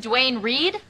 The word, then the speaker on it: Duane Reade: I even bought new pantyhose from Duane Reade. – You mean Dwayne Reed? – Oh, is that how you pronounce it? – Well, we're here.